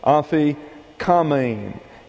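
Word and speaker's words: aphikomen: This is an omicron, a short English O pronounced like a short English A. Afi kamein is the way it is pronounced.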